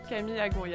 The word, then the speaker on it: Camille Agon: So it's spelled A-G-O-N, but you're French, correct? – Exactly. – So it's very Francois. So it's Camille Agon. – Camille Agon, yeah, perfect.